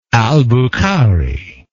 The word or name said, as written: albukhari